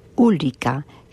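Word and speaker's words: olycka: Pero es que hay otra palabra que se pronuncia casi igual, pero que se escribe diferente, que es ulika, que significa accidente, y que es el contrario de lika, que significa felicidad.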